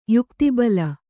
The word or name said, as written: ಯುಕ್ತಿಬಲ